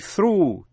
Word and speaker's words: through: Nhưng chữ through, T-H-R-O-U-G-H, through hay là throughout thì G-H câm, O-U đọc là U. Một chữ nữa là rough, R-O-U-G-H, rough.